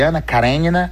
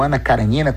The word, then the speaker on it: Anna Karenina: além de Anna Karenina, ou Anna Karenina, como se fala aqui no Brasil, além de A Morte de Ivan Ilitch e A Confissão, dois livros maravilhosos que li recentemente.